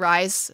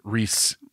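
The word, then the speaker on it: Rhys: Neither does having Rice, is that how you say his name? – Reese. – Neither does having Reese play an Australian.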